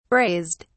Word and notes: braised: British English

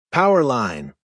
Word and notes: power line: American English

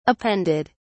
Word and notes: appended: British English

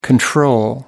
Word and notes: control: American English

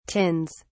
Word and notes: tins: British English

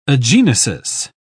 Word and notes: agenesis: British English

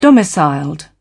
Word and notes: domiciled: British English

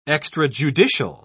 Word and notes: extrajudicial: British English

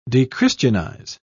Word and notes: dechristianize: British English

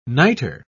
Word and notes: nitre: British English

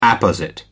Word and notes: apposite: American English

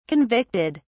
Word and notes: convicted: British English

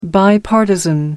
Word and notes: bipartisan: American English

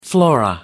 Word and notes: flora: American English

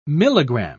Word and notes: milligramme: British English

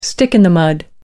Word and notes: stick in the mud: American English

